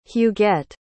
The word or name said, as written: huguette